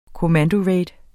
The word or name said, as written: COMMANDORAID